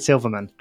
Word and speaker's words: Silverman: Hello, David Silverman.